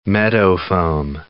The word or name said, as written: meadowfoam